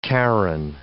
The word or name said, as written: Charon